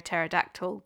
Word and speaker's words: pterodactyl: Why can't you hear a pterodactyl go to the toilet? – Because the P is silent.